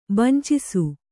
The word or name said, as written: ಬಂಚಿಸು